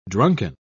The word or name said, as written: drunken